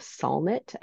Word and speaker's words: Solnit: There's this book called Hope in the Dark by the author Rebecca Solnit, I think is how you say it.